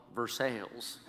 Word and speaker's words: Versailles: Versailles, Kentucky is spelled like Versailles, but since it's Kentucky, they call it Versailles.